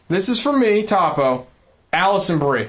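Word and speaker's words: Alison Brie: He wants to know, who is hotter, Allison Brie, I think that's how you pronounce her name. – Yeah, it is.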